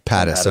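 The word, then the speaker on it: Pattiz: Now, I know you've interviewed a lot of, people that, work for some of the networks like Norm Patz. I think I'm saying his name right, the guy that started Westwood One and is with Podcast One. – Pattis.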